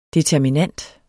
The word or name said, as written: determinant